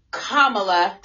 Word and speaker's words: Kamala: But in light of the election and Kamala, did I say it correctly? – Yes, you did.